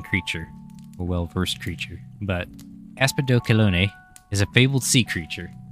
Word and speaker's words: Aspidochelone: Well, with some help from Loki, I have figured out that it is pronounced Aspidochelone.